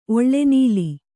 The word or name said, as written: ಒಳ್ಳೆನೀಲಿ